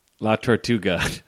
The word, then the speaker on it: La Tortuga: How do you say his nickname? – La Tortuga? – La Tortuga. Okay.